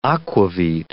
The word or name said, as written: aquavit